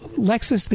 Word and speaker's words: Lexis: And uh, they're spelled differently, but they're pronounced exactly the same. So Lexis, the you know, database.